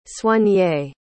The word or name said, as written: soigné